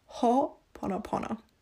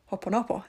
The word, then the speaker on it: Ho'oponopono: I realized that I was pronouncing it weird in the last podcast. And I Googled, like, how do you pronounce it? And it's Ho'oponopono. At least that's more correct than what I said last time, which was Ho'oponopono, I think.